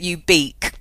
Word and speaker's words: ubique: And but I quite like the fact that ubique, pronounced like that, sounds a bit like soft and chewy in a way.